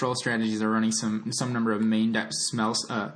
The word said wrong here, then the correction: Spell Snare: Spell snare.